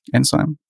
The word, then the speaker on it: Enzyme: Es gibt dann noch Enzyme, ist das richtig ausgesprochen? Oder Enzym? Enzyme. Genau. Und ähm, für Vue gibt es halt noch die Vue-Test-Utils an der Stelle.